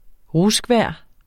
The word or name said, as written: RUSKVEJR